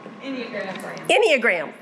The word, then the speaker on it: Enneagram: She is going to lead us in a study that you may have heard of called the Enneagram. Is that how you pronounce it? – Enneagram.